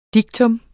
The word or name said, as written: diktum